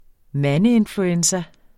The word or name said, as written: mandeinfluenza